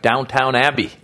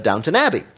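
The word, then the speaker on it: Downton Abbey: Make it look like Downtown Abbey. – That's right. That's not how it's pronounced, though, Maz. You know that. – Not really the PBS crowd, apparently. No. Downtown Abbey.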